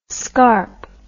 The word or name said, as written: scarpe